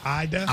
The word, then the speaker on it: Ada: It's A-D-A. – I think it's Ada. – I don't believe that. You're telling me they pronounce A-D-A, Ida? – I'm almost certain it's Ida, Oklahoma. – Look that up. – That's cool. – How would A-D-A be pronounced Ida?